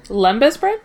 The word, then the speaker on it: Lembas: Lembas bread.